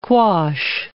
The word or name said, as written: quash